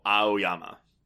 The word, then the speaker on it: Aoyama: But when I was reading the book, I was saying Aoyama. Like, it's like you're pronouncing the A-O. Aoyama.